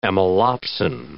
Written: amylopsin

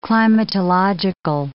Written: climatological

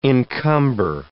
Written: encumber

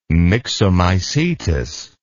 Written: myxomycetous

